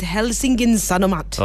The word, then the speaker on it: Helsingin Sanomat: I'll let you pronounce this name. – I think it's Helsingin Sanomat.